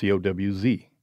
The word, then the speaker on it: Coues: And he has a footnote that says, "Here's how my family name is pronounced: COWZ."